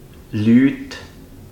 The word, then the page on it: Lüt: züritüütsch